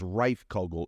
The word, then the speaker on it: Rijfkogel: That last name of mine is Rijfkogel. It is a Dutch name, R-I-J-F-K-O-G-E-L.